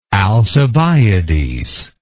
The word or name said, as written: alcibiadean